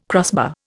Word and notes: crossbar: Indian

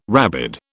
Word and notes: rabid: British English

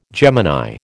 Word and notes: Gemini: American English

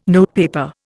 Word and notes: notepaper: Indian

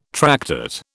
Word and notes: tractate: British English

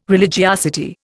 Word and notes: religiosity: Indian

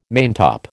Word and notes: maintop: American English

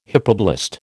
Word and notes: hypoblast: American English

